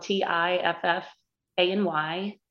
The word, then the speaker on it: Tiffany: Um, so it's spelled T I F F a N Y L for my middle name, Cantrell, C A N T R E L L at Gmail.